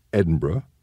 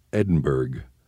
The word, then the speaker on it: Edinburgh: The Edinburgh in Scotland has somewhat of a silent G and H at the end, pronounced Edinburgh. The one in Texas has no H, but does pronounce the G, Edinburgh.